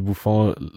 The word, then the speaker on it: Buffon: Also ich musste mir diese Gigi wie Buffon, habe ich das richtig ausgesprochen? – Ja, auf jeden Fall.